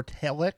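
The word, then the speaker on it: Talajić: Dalibor Telic. – I don't know. – Usually when it's a J-I-C it like that, you don't have the J sound at all.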